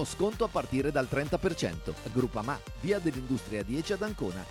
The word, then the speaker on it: Groupama: Groupama. Ah no, si pronuncia Groupama.